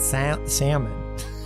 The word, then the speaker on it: Salmon: And Amminadab begat Nashon, and Nashon begat Salmon. Straight up salmon. – That's interesting. – Nashon begat salmon, spelled like salmon.